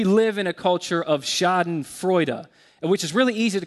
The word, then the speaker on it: Schadenfreude: I don't know if you've ever heard the term, I believe it's pronounced Schadenfreude. It looks like Schadenfreude to us.